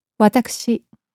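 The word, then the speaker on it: わたくし: Watakushi. – Note how the u, which falls between the k and the sh, is not vocalized.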